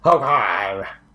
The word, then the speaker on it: Hoquiam: We had just settled near Hoquiam. I imagine that's how that's pronounced, I'm sure. – I think you nailed it. – Hoquiam.